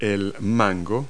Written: mango

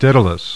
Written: DAEDALUS

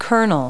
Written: kernel

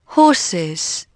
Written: horses

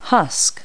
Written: husk

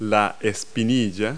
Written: shin

